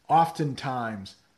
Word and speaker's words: oftentimes: Angelo is wondering, how do you pronounce oftentimes? Yeah, I guess the T is a little more pronounced there.